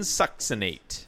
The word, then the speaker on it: succinate: Doxylamine succinate. – I listened to one chemist pronounce it to see what it is. I think it's a succinate. – Succinate. – Yeah, so nice. We confirm this.